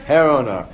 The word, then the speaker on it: Girona: That's how it's pronounced in Hebrew. I'm sure the correct Spanish pronunciation is something else. What is it? Herona. In Hebrew, they always put it with a little gimel, so it's actually Girona, and I'm sure that was not, I knew, what I did know is that was for sure not the right Spanish pronunciation.